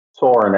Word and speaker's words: Soran: Soran, actually.